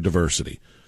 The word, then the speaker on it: diversity: There's not a long I in the word diversity. Diversity is not, it's not with a long I. It is pronounced diversity, not diversity.